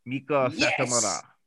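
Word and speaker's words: Meiko Satomura: Yoshi legend Miko Satomura. Have I pronounced that correctly? – You have. Miko Satomura. Or Satomura Miko.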